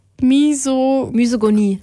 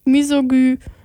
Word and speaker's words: Misogynie: Und da spricht sie eben unter anderem auch sehr viel über feministische Themen und Misogynie. Scheiße, das ist jetzt Misogynie. Das Y wird wie ein Ü ausgesprochen, ne? Misogynie.